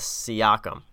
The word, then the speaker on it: Siakam: I kind of wish you were pronouncing it, but it's Pascal Siakam. Eastern Conference Player of the Week. Or I think the actual way to say it is Siakam. – Siakam? – Yeah, Siakam.